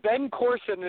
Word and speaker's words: Ben Carson: Yes, Ben Carson.